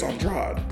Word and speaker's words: Andrade: Is it Andre or is that how you pronounce his last name? – What did I just say? – Andre. – I think it's Andrade. – That's what I was asking because I'm like, I thought it was Andrade. – Always, yeah. Because then you'll go in some other areas where they have other Andrades and they pronounce it Andre.